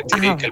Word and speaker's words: Tíric: No, ho has pronunciat molt bé. Tíric, el príncep d'Ilercavonio.